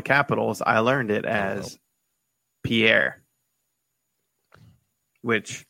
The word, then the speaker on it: Pierre: But the people in South Dakota pronounce it Pierre. – Pierre. P-E-E-R. But it's like P-I-E-R-R-E.